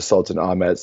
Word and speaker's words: Sultanahmet: Firstly, to start off, I think everyone needs to be looking at the Sultanahmet neighborhood. That's mm-hmm. how you say it colloquially, but it's named after the Sultan Ahmet. So it's literally Sultan Ahmet.